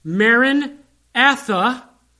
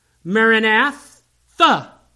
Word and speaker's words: Maranatha: And it can be pronounced two different ways. It can be pronounced Maranatha, our Lord is coming, or it can be pronounced Maranatha, our Lord come.